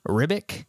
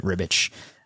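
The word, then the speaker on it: Ribić: Excuse me if during the course of this talk and this entire podcast, if I slip up and call him Ribic, because that's how I've said it forever until today when I looked up an interview with him and saw him that he pronounces it Ribich.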